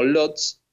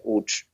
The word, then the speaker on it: Łódź: Es lo que muchas veces leemos como Lodz, pero en polaco se pronuncia Łódź.